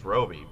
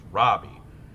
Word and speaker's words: Robbie: I'm like, oh shit, they wrote about it prominently, and they had asked her, and she said people have always said it as Roby, but it's pronounced Robbie, and I'm like, okay, maybe my saving grace here is that this is a recent phenomenon.